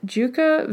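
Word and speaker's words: Dieuwke: One is called Starstruck, and it's by Juca Van Mulligan. And her first name is spelled not at all like it sounds. It's D-I-E-U-W-K-E.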